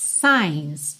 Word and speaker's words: signs: Depois que volta ao refrão, red lights, stop signs. Signs, esse G é mudo, tá?